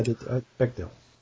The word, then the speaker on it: Bechdel: And that also would have made the movie pass the Bechdel test. Am I pronouncing that right? – Yeah. – Okay. – Yeah, Bechdel.